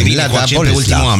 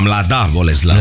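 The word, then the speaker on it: Mladá Boleslav: Zice, știați că în fiecare zi călătoriți cu autobuzul meu prin Mlada Boleslav, în Cehia. Nu știu dacă am pronunțat bine. – Mlada Boleslav. Mlada Boleslav. – Mlada Boleslav.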